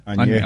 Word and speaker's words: Añejo: Añejo.